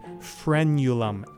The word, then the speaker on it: frenulum: And that odd word is frenulum, F-R-E-N-U-L-U-M. It rhymes with the word pendulum.